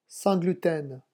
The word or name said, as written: sans gluten